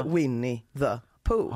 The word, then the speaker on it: Winnie the Pooh: Närmare bestämt filmen Winnie the Pooh, eller för, förlåt, så här uttalas den, Winnie the Pooh, Blood and Honey.